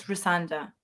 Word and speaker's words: Rosanda: I want to say like Rosalind, but I know it's not Rosalind. – Honestly, I've had every single pronunciation that I can ever think of, but it's Rosanda.